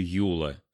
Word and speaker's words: Юла: Муж еще до брака, когда они только познакомились, сразу начал называть ее Юла с ударением на первый слог.